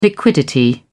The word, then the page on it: liquidity: British English